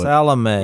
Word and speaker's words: Salome: So it says, when the Sabbath was over, Mary Magdalene, Mary, the mother of James, and Salome is what. – Salome. – We're going to go.